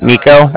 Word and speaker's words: Niko: So, like I said, my name is Nico. That's spelled N-I-K-O. And then the Bank of Cardiff is spelled C-A-R-D-I-F-F, as in Frank, Frank.